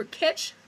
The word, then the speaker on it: Kitch: And once again, remember kitch to rhyme with bitch. – Yeah, K-I-T-C-H.